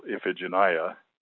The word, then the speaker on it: Iphigenia: A 5-syllable name, you generally have the pattern accent on the first and a lesser accent on the fourth. So, for example, Iphigenia or Iphianassa, those are 2 5-syllable names which have accents on both the first and fourth syllable.